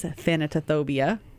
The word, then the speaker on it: thanatophobia: So when we come back, we're going to start talking a little bit about what is thanatophobia.